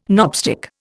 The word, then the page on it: knobstick: Indian